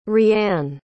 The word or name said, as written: Rianne